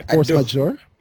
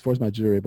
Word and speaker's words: force majeure: Force majeure. I don't think it's forced my jury, but I'm, I am not a lawyer at all.